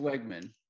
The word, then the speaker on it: Weigman: So is I, it Wayman, Weigman? – How do you say? It's it? Weigman. Weigman. So I, it, it, bro.